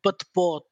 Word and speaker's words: batbout: It's called batbout. That's how you say it.